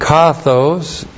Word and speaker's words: kathos: And then kathos, K-A-T-H, long O-S.